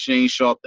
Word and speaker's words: Shane X Sharp: When you hear that, when you hear motherfucker rapping, it's Shane Sharp, the X is silent, and, you know, it stands for motherfucking.